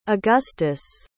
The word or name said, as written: agustus